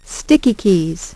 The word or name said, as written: Sticky Keys